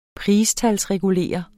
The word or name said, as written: PRISTALSREGULERE